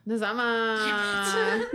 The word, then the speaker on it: Nazama: I'm scared. Nazima. – Nazama. – Nazama. Ah! I'll get it right. – It's going to be great. – Nazama. Nazama. – It's like Manzana, only yeah.